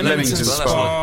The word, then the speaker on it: Leamington Spa: Yeah, have I pronounced that right? Leamington or Leamington? – Leamington. – No, Leamington Spa.